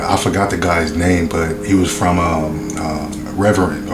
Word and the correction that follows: Revenant: or how you say it? – Revenant.